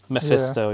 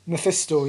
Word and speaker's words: Mephisto: I think we might be going more down the route of they are fragments of them, and I can't say his name properly, Manifesto? – Mephisto, yeah. – Mephisto, yeah.